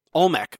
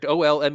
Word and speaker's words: Olmec: Olmec. – I don't think you're pronouncing it correct, but yes, you are correct. Olmec, the Olmec civilization, the precursor to the Aztecs, and sort of same time as some of the Mayans.